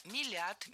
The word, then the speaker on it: мелят: Правильно – мелят, мелит, мелим, мелишь. Ударение в этом слове всегда стоит за корнем, а корень остается безударным. Для запоминания держим в голове начальную форму.